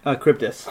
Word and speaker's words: Charybdis: Charybdis. – Charybdis.